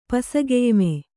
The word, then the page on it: ಪಸಗೆಯ್ಮೆ: Kannada